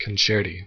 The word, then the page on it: concerti: American English